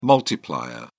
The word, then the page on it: multiplier: British English